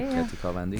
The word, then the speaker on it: Kati Kamandi: I'm insecure about whether I said the name right. Did I say it right? – Yeah, you said it right. Kati Kamandi.